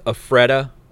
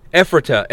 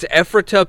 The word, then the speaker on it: Ephrata: Are you familiar with Afreda? Afrada? – Afreda Stairs? – Let's see. – Frida yeah, Kahlo. – Ephrata. It's Ephrata, Pennsylvania is how you say it.